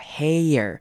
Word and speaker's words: hair: hair 有点先念 A 再念 R 的声音，这样整个把 air 咬完之后才会听起来像是长母音 A 的声音，所以应该念成 I love my white hair. 大家可以多练习或是多揣摩这个长母音 A 后面接 R 的念法 hair.